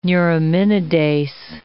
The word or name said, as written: neuraminidase